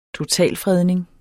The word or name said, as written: totalfredning